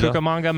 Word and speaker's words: Cucamonga: Cucamonga Mexican in DCA.